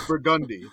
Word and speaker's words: Burgundy: It is Burgundy. That is the pronunciation of it. Street in Louisiana, in New Orleans.